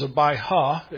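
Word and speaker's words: Abijah: Old Testament is Abiha. It has a J, silent J in it.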